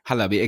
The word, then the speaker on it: Halabi: It's Halabi. It's Halabi, exactly.